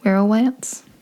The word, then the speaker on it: werowance: Like, Powhatan was the one who welcomed him in, referred to him as kind of like a son, made him a, I think you told me how to say this word. – Werowance? – Yeah,